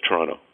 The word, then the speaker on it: Toronto: Second T is silent. It's Toronto. – What? – Yeah, it's Toronto. If you pronounce it T-R-O-N-O, Toronto, or T-R-O-N-A, Toronto, you have it right. That's how you talk like a native.